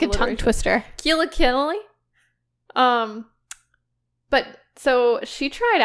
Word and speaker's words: Keala Kennelly: No, Keala Ken- Keala Kennelly. Sorry. So Keala Ken- Kennelly.